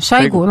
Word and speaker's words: Шойгу: Шойгу, правильное ударение? – Шойгу. – Ну, опять я ошиблась.